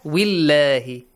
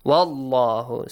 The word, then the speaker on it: Allah: So, like what? Wa. Wa has a fatha. Wallahu. If it was a kasra, willahi. Right? Wallahu. So, good job.